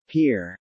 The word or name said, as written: Pier